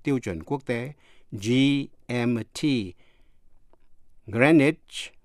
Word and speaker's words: Greenwich: Biệt lệ là CH phát âm là CH như trong chữ Greenwich, G-R-E-E-N-W-I-C-H, Greenwich, ở London,